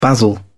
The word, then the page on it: basil: British English